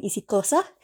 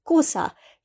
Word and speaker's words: isiXhosa: grew up with the language cannot do the clicks that is well known in isiXhosa, but it is usually pronounced with Kosa if you cannot pronounce the clicks.